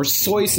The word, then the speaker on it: Seuss: His mother is Henrietta Seuss, or Seuss is actually the correct way of pronouncing the name. Seuss is his mother's maiden name. That's where he gets the name Seuss from. Seuss always said that, it rhymed with Mother Goose, or somebody pointed that out to him, so he was always okay with people pronouncing it Seuss.